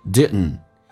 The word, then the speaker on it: didn't: 下面一个单词在口语化当中有时候速度加快的时候经常会把第二个 D 会省略，也就是说 D 跟 N 组合在一起 D 是可以不发音，气流阻塞一下就变成 didn't, couldn't 说成 couldn'tShouldn't, shouldn't, shouldn't.